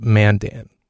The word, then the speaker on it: Mandan: is it pronounced Mandan? And I was trying to be funny because it seemed ridiculous. She goes, yep. I was like, really? It's pronounced Mandan? I'm like, you're not just messing with me. You're not just setting me up to look like a moron, are you? And she goes, no, it's seriously pronounced Mandan, which anybody else in the country would call it Mandan.